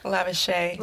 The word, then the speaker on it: Lavishea: How do you say that word again? – Lavishy. – Lavishy. – Like shea butter. – I like shea butter. Lavishea.